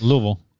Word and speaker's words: Louisville: Actually, it's Louisville. You know, Paul is from Louisville. – Yes. Louisville. – So you don't pronounce it all. – Because, yeah, Louisville is a one syllable word.